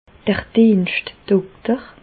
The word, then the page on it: d'r Dienschtdokter: Bas Rhin; Reichshoffen